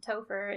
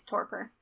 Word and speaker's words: torpor: I'm just adding an H to the P unintentionally and saying topher. Torpor. I don't know. T-O-R-P-O-R. How would you say that? Torpor? – Torpor? – It's a weird word. Torpor. So there's no H to make an F sound, but I was adding one unintentionally.